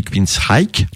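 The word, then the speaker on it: Queensrÿche: Alors moi j'ai toujours prononcé Queensryche, mais il paraît que dans les pays anglo-saxons on dit Queensryche. – Oui, parce que le che n'existe pas en anglais.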